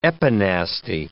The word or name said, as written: epinasty